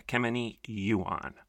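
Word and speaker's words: Ekemini Uwan: Latasha Morrison is also a close friend and associate of a woman, and I believe you pronounce her name, Akemeni Yuan. I'm going to spell it if you want to look it up. EKEMINIUWAN, Ekemini Yuan.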